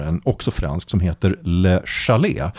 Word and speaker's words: Le Chalet: Den heter, här är jag faktiskt, vilket är väldigt ovanligt, mer säker på det franska uttalet. Det heter Le Chalet. Jag tror man säger The Chalet på engelska, men jag är inte riktigt säker. – The Chalet.